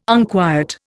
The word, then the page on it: unquiet: Indian